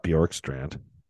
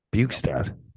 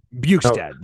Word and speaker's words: Bjugstad: I think that the moves at the deadline, the Matthias Ekholm, the Bjorkstrad, I'm going to get Bjorkstrad. – Name. Yeah, I'm going to butcher that one. – Who else? Not Bjorkstrand. Bjugstad. – Bjugstad, there we go.